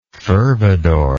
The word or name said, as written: fervidor